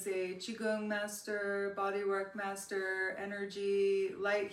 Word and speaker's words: Qigong: Qigong session that you guys can check out.